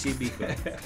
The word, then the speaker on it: Beacon: Beacon, si legge Beacon? – Sì, Beacon.